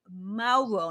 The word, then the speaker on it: Mauro: It's spelled, it's pronounced the way you spell it is M-O-R-R-O-W. But it's spelled the Italian way. So M-A-U-R-O. So you pronounce it as if you were going to spell it M-O-R-R-O-W.